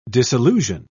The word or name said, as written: disillusion